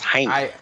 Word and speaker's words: Jaime: Jimmy Munguia is fighting Takashi Inoue. – I think it's pronounced Jaime. – Jaime. Oh yeah, they call you Jaime.